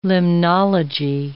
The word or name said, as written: limnology